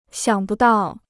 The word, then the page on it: 想不到: xiǎng bu dào